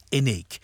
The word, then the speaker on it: INIC: It's got an unusual spelling, but it's pronounced INIC.